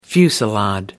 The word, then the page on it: fusillade: American English